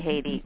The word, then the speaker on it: Haiti: Yeah, I'm kind of into Haiti. I've been there a couple of times. – I heard you say it right. You were like, Haiti.